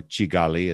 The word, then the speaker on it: Kigali: There are at least three or four different major strains of work, major organizational efforts that Fiacre Mutabazi has created in Kigali, or Chigali as it's pronounced locally. It starts with a K.